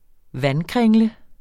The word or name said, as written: vandkringle